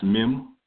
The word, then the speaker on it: mem: Then you have M, which is um, basically um, it's pronounced mem, and you have water, so the door of water or the door of blood, mighty chaos, other definitions.